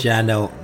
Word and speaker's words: Janelt: Janelt, yeah, the man machine, mate.